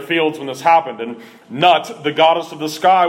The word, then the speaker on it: Nut: This was an attack on the Egyptian deity called Nut, and it's spelled exactly like it sounds, N-U-T, Nut, the Egyptian goddess of the sky.